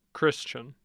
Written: christian

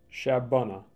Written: Shabbona

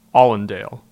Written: Allendale

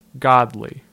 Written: Godley

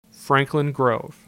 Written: Franklin Grove

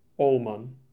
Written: Ohlman

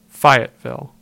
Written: Fayetteville

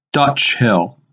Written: Dutch Hill

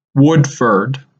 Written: Woodford

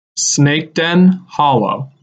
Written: Snakeden Hollow

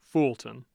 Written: fulton

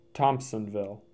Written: Thompsonville

